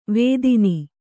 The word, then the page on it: ವೇದಿನಿ: vēdini